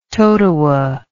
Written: Totowa

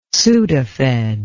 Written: sudafed

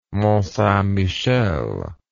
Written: montsaintmichel

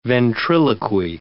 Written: ventriloquy

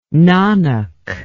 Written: Nanak

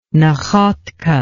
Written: Nakhodka

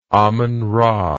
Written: amonra

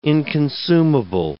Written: inconsumable

